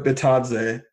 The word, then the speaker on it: Bitadze: In Goga Bitsa, I don't know why I tried to say his name. – Batadze, I believe. – Goga Bitadze.